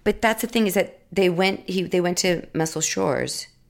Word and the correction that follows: Muscle Shoals: right? Is that how you say it? – Shoals.